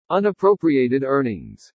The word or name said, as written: UNAPPROPRIATED EARNINGS